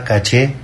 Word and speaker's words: caché: Lo importante aquí es que en cualquiera de estos casos la palabra caché está castellanizada y se escribe así como suena. Caché con acento en la E.